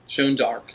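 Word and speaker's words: Jeanne d'Arc: Joan d'Arc. It's, it's the French pronunciation.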